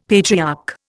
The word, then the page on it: patriarch: Indian